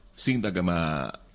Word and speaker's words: Sintagma: Sintagma se pronuncia así en griego, ha seguido y ahora espero que sigan también Italia y Portugal, que están muy mal.